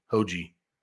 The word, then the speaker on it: Hoge: Is it Hoge or Hoagie? Hoagie.